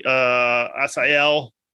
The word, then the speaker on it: Assael: Well, Dr. Romanelli, Assael. No, I think I've nailed that pronunciation. I feel good about that.